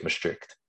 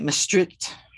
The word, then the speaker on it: Maastricht: You got to get it back in the throat. – Okay, that's not going to happen, but it's not Maastricht. I've been putting the emphasis on the wrong syllable, right?